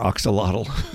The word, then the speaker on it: Axolotl: Axolotl. Well, I looked it up, and I phonetically wrote A-K-S-U-H-L-A-W-T-A-L. Axolotl.